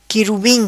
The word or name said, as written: quirubim